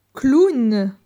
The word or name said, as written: Clown